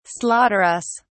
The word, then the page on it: slaughterous: British English